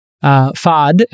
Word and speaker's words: Fahd: And Fahd is, and it's spelled F-A-H-D if you're wondering, is a general partner at Roach Capital.